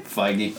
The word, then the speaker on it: Feige: Is that pronounced his name? – Feige. – Oh, Feige.